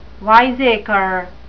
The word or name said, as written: wiseacre